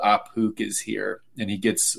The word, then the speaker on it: Ahpook: Apuk, A-H-P-O-O-K.